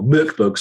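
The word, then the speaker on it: Mörk Borg: They've been working with the kind of open gaming license, essentially, that you get from working with Morkborg to create what effectively is a monster manual for that use with Morkborg, or Mirkburg, sorry, that's how you say it, Mirkburg.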